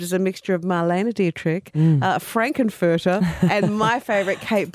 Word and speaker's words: Dietrich: And I feel like Marlene Dietrich is obviously an icon.